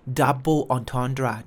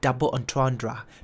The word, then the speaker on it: double entendre: double entendre นะครับสะกดนะฮะ d o u b l e เวนวัก e n t e n d r e double entendre